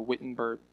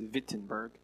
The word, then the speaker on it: Wittenberg: Martin Luther nailed the 95 Theses on the Wittenberg, or it's actually pronounced Wittenberg, church.